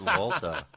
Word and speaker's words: Walther: I think it's German, and so it's pronounced Walter.